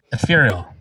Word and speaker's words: ephemeral: How do you say it? – Ephemeral.